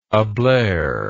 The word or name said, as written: ablare